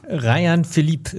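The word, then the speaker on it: Rayan Philippe: Also erstmal ist er ein Franzose, von daher spricht man ihn, glaube ich, Rajan, oder? Du bist der Französisch-Experte, Rajan. – Rajan Philipp ist die Sprechweise, die ich kenne.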